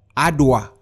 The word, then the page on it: adua: Efik